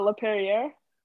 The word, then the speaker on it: Laperriere: Laperriere. Like I'm not French, my parents are, but maybe wait, it's said a different way. That's how I would say.